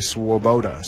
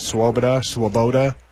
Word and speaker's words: Swoboda: That's definitely who that is Eli Swoboda. Swoboda. As I was getting the official pronunciations from the coaches before in the pregame, they said, do it either way, it'll be fine. And